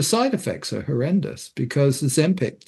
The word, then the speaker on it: Ozempic: Ozempic. – Ozempic, yeah.